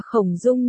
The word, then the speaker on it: Khổng Dung: Khổng Long nhường lê, Tú Tú là nhường thịt khô. Hi hi, Quan Đại Bảo lầm bầm một câu. Là Khổng Dung nhường lê.